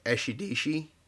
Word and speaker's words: Esidisi: it was pronounced like Eshidishi. And for whatever reason that just didn't click. And when you see it, you know, spelled out, cause it's like E-S-I-D-I-S-I, And the way they pronounce it, it didn't sound right.